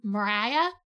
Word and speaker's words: Maria: It's spelled Maria, but in every single adaptation I've seen, it's been pronounced Mariah. So I will refer to her as Mariah, but I'm not sure if that's actually correct. That's just how it's been pronounced in adaptations.